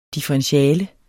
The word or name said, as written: differentiale